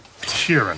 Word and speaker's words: Sheeran: Frank Sheeran. Am I saying that right? – Yeah, you said right.